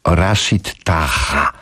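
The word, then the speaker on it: Rachid Taha: Rashid Taha. Tak to się powinno wymawiać.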